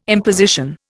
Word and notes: imposition: Indian

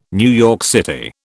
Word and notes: NYC: British English

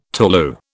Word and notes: tolu: British English

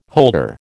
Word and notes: holder: American English

